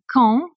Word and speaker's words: Caen: I come from Normandy, from a town called Caen, C-A-E-N, it's spelled. People always get it confused with Cannes, but I assure you it's not nearly as glamorous.